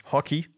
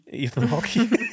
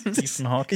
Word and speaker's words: Ethan Hawke: Ethan Hawke, ich kann diesen Namen nicht mehr normal lesen, weil unser ehemaliger Praktikant, der Paul, der hatte früher immer gedacht, dass so, wenn so ein E hin dran ist, das noch ausgesprochen wird. – Hockey. – Ethan Hawke. – Ethan Hawke.